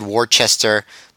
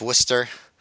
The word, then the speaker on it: Worcester: Well, before you go on, I have to correct you, because Joe Gagne is going to get really mad that you said Worcester, but it is pronounced Worcester.